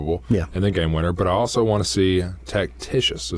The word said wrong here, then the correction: Tacitus: Is that how you pronounce it? – Oh, and that's my other one. Yes. Tacitus.